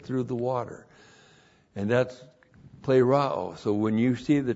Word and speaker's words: plerao: The universal indwelling of God the Holy Spirit, the filling of the Spirit, which means to be empowered, the word is plerao.